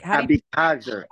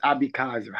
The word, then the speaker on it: Abeckaser: Abeckaser. – I know, I keep... Abeckaser is really how you pronounce it, the Israeli way. – It's Abeckaser.